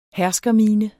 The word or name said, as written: herskermine